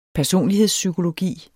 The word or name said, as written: personlighedspsykologi